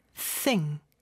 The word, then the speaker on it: think: think 발음할 때는 요 th 발음 중요하죠. 쌍시옷 발음 아닙니다. th 발음을 할 때는 여기서는 무성음인데요. 윗니와 아랫니가 다 닫힌 상태가 아니라 살짝 뗀 상태에서 그 사이에 혀가 껴있다가 뒤로 쏙 빠지면서 바람을 내뿜는 소리예요. 그래서 th, th 이런 소리예요. think, think, think 이죠.